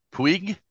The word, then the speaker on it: Puig: The Spaniard, David Puig, is that how you say his name? – Yes, former Arizona State star.